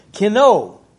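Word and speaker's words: Kino (κενόω): You see, the Greek word used here in verse 7, in Philippians chapter 2, verse 7, is kino, say it right here. My tongue is getting tangled again here. Kino. K-E-N-O-O. It's not ooh, but kino, okay?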